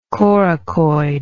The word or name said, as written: coracoid